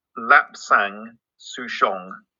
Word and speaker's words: Lapsang Souchong: It's pronounced Lapsang Souchong.